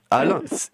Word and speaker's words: HAL: Et donc pour ça, nous, on a, beaucoup entendu euh, parler de l'archive ouverte pluridisciplinaire HAL ou HAL. honnêtement, je ne sais jamais trop comment le prononcer, donc peut-être que vous pourrez... – HAL, c'est bien. – HAL, eh ben, c'est parfait.